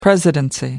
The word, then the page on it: presidency: American English